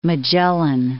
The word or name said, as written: Magellan, Strait of